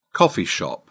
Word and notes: coffee shop: British English

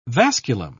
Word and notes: vasculum: British English

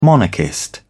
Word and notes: monarchist: British English

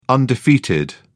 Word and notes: undefeated: British English

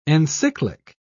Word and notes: encyclic: British English

